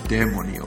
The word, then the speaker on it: Dèmoni: Okay, unser erster Film heute Abend ist Dämoni. Danke übrigens, Daniel, für die Aufklärung, dass tatsächlich Dämoni ausgesprochen wird.